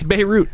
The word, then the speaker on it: Beirut: Either way, it's Beirut. The little thing where how you pronounce it is B-A-Y-R-O-O-T.